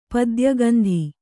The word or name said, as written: ಪದ್ಯಗಂಧಿ